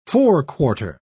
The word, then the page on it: forequarter: British English